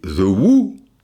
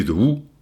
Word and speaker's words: The Who: Alors les Who, eh ouais, mais ça s'écrit W-H-O. Donc même avec une prononciation anglaise à la française, je dis The Who, je dis, non, là, je suis conditionné parce que je sais que c'était où. Je dis de où, tu vois parce que ou, ou, ça veut dire qui que quoi, quoi.